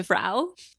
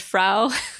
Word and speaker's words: Fra: I think the way they say it in the show is Fra, and it's spelt Fra. I think it's just that it's so close to the word Frau. that we start pronouncing it that way by accident.